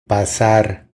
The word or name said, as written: Pasar